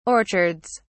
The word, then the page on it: orchards: British English